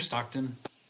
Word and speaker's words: Stockton: Am Stockton. I saying that right? – Yeah, near Stockton.